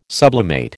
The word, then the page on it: sublimate: American English